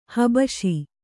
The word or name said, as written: ಹಬ್ಬುಸೆ